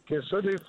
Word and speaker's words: Kissimmee: Kissimmee, Florida, the land of Mickey and Minnie.